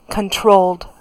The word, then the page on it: controlled: American English